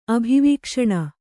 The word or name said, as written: ಅಭಿವೀಕ್ಷಣ